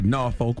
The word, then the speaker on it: Norfolk: They make sure to tell you to pronounce it Norfolk without the R.